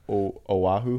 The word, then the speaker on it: Oahu: Oahu.